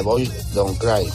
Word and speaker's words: Boys Don't Cry: No sé si lo he dicho bien, pero bueno, creo que lo habéis entendido. – otra de las canciones eh, míticas de, que forma parte de la, de la, historia de muchas, de muchas personas, de la historia de la, de la música, en este caso de Cure con Boys Don't Cry. Lo ha pronunciado de maravilla Carlos de Toledo. Perfectamente.